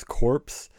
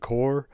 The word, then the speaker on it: Corps: The one thing I can't forgive, but I'm not going to take away points for, is how many people refer to the Corps, the Militech Corps, the Army Corps, whatever, as corpse. Female V always called it Corps. Johnny Silverhand always said corpse, even though he was a member of the Corps at one point.